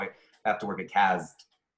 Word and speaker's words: CAZT: He'd always be like, you're the only person that pronounces the Z. And it's like, no, I'm now with Seth. And we are the only two people in Los Angeles that pronounce the Z in CAZT.